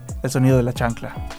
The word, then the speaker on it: El Sonido de la Chancla: Con Quique y Alex de El Sonido de la Chancla.